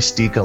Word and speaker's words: Sticha: And my first name is spelled C-O-R-Y. I'm at Twitter, Facebook, Instagram, Untapped, all that kind of fun stuff. F-R Corey Stica, last name spelled S-T-I-C-H-A.